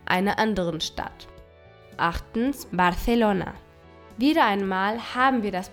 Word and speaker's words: Barcelona: Das C mit einem E oder I wird als Z ausgesprochen und nicht als S. Barcelona und nicht Barcelona.